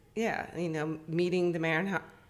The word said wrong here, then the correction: Maynards: I'm going to say their last name wrong. – Maynards. – Maynards. – Yeah.